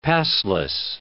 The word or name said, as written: pastless